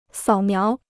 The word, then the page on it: 扫描: sǎo miáo